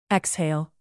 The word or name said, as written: exhale